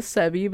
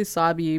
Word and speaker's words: Sabi: Like I thought the main character's name was Sebi, but it's actually Sabi. But they're Canadian, so they pronounce it Sebi.